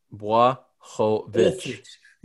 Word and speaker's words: Błachowicz: You got to say it right. Bois. Jan. It's time to start pronouncing it the correct way. – Yeah. Błachowicz.